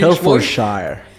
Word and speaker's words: Hertfordshire: Me gustó mucho tu pronunciación, tu cute British pronunciation sobre Hertfordshire.